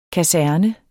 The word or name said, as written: kaserne